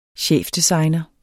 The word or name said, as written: chefdesigner